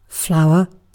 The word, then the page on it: flower: British English